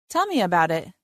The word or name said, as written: Tell me about it